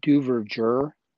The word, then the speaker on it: Duverger: So, but knowing what I knew about Duverger's law, and by the way, it's spelled Duverger, D-U-V-E-R-G-E-R, pronounced Duverger.